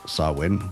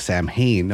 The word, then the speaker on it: Samhain: In modern times, Samhain, a Gaelic word pronounced Samhain, which is it throws people off because the way it's spelled. It's spelled S-A-M-H-A-I-N. People don't understand how to say that. They think Samhain. No, it's Samhain.